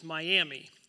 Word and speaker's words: Miami: Uh, Miami is a town that, if you look on a map, you think it's pronounced Miami. It's spelled the same way, but we're Okies and we're weird and we pronounce it differently because that's what we do with towns in Oklahoma.